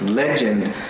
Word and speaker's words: legend: Legend, which the accent comes on the le, so it's legend.